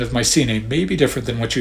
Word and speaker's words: Mycenae: Some people pronounce the C in Mycenae hard, like a K, Mycenae, or you may hear the Y pronounced as a short I, Mycenae, and so on.